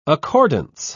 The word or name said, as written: accordance